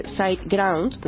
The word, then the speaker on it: SiteGround: Tenemos también a José Ramón Padrón, que es el CEO de SiteGround, lo he dicho bien, ¿no? – Efectivamente.